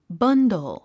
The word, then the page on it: bundle: bʌ’ndl; バンドル